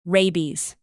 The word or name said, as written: rabies